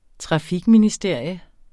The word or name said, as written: trafikministerium